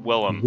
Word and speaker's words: Willem: William Dafoe? – Yeah. Willem. – Willem.